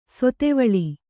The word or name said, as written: ಶಾದ್ವಲ